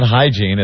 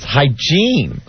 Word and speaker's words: hygiene: And not hygiene, it's hygiene. – Hygiene. – That's the accent on the wrong syllable.